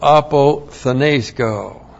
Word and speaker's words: apothenesko: And then we have the verb apothenesko. A-P-O-T-H-N. Long E-S-K long O. Apothenesko.